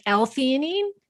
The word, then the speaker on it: theanine: So, my first one that I wanted to ask you about is theanine. Is that how you say it? – Like L-theanine. Yeah.